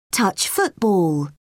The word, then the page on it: touch football: British English